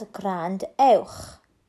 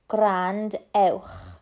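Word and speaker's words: gwrandewch: It is a command for listen. You pronounce it as grandewch. Grandewch. Grandewch. Repeat after me, grandewch.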